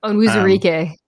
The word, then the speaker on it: Onwuzurike: Levi Onwuzurike, I'm probably mispronouncing that. – Onwuzurike, yeah.